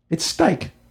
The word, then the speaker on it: Stike: His name is, I believe, I want to say it right because I've read it a million times, but it's Stike. S-T-I-K-E, Stike.